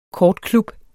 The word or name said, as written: kortklub